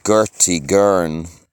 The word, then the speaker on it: Gortighern: The original language was called Gurti Gurn, G-O-R-T-I-G-H-E-R-N, but the Latins called it lingua humana. The H is silent.